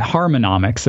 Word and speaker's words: Harmonix: Yeah, so it's actually Harmonix. There's an extra syllable in there, which I will admit is not a spectacular name, but it's kind of clever.